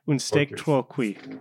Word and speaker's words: Un steak trop cuit: The Luc Moulet film, Un steak trop cuit. Is that correct? – Trop cuit. – Un steak trop cuit. Yes. Mm-hmm.